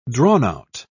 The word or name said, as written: drawn-out